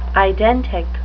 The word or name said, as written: identic